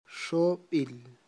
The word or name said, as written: xob'il